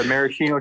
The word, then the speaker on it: maraschino: I'm gonna butcher it wrong, they're like the Maschino cherries or whatever, those real red ones are like cocktail cherries or some shit. – Yeah, the maraschino cherries. – There you go.